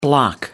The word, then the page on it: bloc: American English